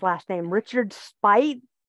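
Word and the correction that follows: Speight: Richard Spate. Mm-hmm. – Yeah. Spate. Yeah.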